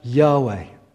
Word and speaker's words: Yahweh: When you pronounce it, it's pronounced something like Yahweh.